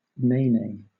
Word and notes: nene: British English

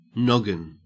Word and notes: noggin: Australian English

